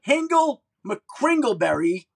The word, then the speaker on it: Hingle McKringleberry: I also want to ask you, is it true that you once paid Von Miller's $11,000 fine for mimicking – you're a Hingle McKingleberry? Am I saying that right? – McKringleberry. – Hingle McKringleberry, yep. His end zone celebration.